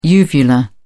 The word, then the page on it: uvula: British English